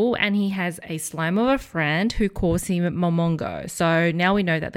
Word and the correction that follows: Momonga: Momonga? Am I saying that right? I just want to make sure. – Yeah. Momonga, yeah.